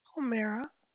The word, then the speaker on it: Homera: Homera. – Can you spell that for me? I just don't want to mess it up. – H-O-M-E-R-A. – K-H-O-M-E-R-A. – No, it's H-O-M-E-R-A.